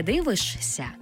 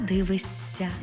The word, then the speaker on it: дивишся: Також пишемо «щся», а вимовляємо «ся». Наприклад, не «дивишся», а «дивисься».